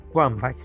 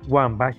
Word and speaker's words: Wambach: Entonces, una doctora norteamericana originaria de Chicago, Helen Wambach, repito el nombre, Helen Wambach, para los que desean buscar información, se escribe con W, Wambach y B larga.